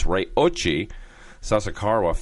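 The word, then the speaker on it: Ryoichi Sasakawa: And the Ryochi, I better say it right because I got corrected by some of my listeners, it's Ryochi Sasakawa Foundation.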